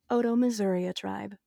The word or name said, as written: Otoe-Missouria